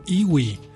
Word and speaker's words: EWI: ¿Qué tal el sonido embrujante de ese instrumento medio exótico que se llama iwi? Se escribe E-W-I, un instrumento electrónico, pero que es de viento al mismo tiempo.